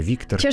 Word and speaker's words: Викторке: Я все время не знаю, куда ставить ударение. О Викторке. – В чешском языке всегда на первый на слог. На первый слог, да.